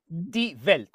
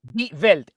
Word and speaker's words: Die Welt: Die Welt. ¿Cómo se dice? Die Welt Die Welt, el mundo.